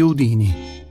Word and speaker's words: Houdini: Uno di questi uomini è stato Harry Houdini, e non con l'accento sulla I finale, come erroneamente si pronuncia.